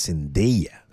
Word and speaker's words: Zendaya: Nunca nadie pronuncia bien mi nombre. Realmente es Zendaya, Zendaya.